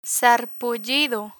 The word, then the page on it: sarpullido: spanish